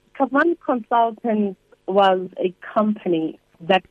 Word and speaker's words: Kavan: I don't know if I'm pronouncing it correctly. Is it Kavan Consultants? – Yes, it is Kavan Consultants.